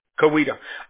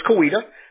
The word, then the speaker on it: Coweta: How do you pronounce it? – Coweta. – All right, so it's Coweta.